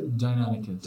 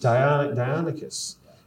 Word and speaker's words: Deinonychus: That actually, the dinosaur was known as a Deinonychus, right? Did I pronounce that correctly? – Deinonychus. – Deinonychus.